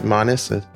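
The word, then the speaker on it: Manis: Manas? – Yes, perfect.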